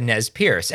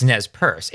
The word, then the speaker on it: Nez Perce: We both said Nez Perce. And it's just something that's just how I pronounced it. And that's wrong. It's not the correct pronunciation. It's Nez Perce. It's French for nose pierce.